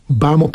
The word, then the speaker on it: Bamaw: Bamo, pisze się Bamaw. – Bamo.